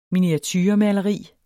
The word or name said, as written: miniaturemaleri